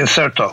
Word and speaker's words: Incerto: Then you had Anti-Fragile, and now you've got Skin in the Game, and you call this entire project The Incerto.